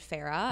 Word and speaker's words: Farah: And that's why I named my brand Farah. Mm-hmm. It means joy in Arabic. – Right. – It also rhymes with Terra, terrestrial, and grounding the earth, Mother Earth, you know.